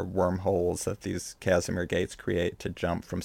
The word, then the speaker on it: Casimir: protected from space, and they've used the Casimir. Is that how you say it? Casimir? – Gate? Yeah, Casimir. Yeah.